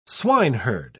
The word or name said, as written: swineherd